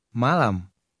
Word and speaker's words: malam: Malam. Malam. – In two-syllable Indonesian words, the stress is almost always on the first syllable.